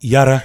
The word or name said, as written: Yara